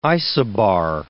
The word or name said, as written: isobar